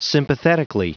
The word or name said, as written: sympathetically